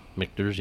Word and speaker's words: Michter's: Michter's, yep.